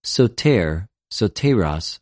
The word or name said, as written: σωτηρ,σωτηρος